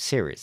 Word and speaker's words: Ceres: I never get used to the American pronunciation of these names. Ceres.